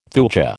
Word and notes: thuja: American English

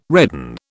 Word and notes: redden: British English

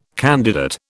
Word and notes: candidate: British English

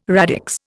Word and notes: radix: Indian

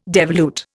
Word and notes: devolute: Indian

